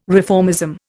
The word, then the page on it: reformism: Indian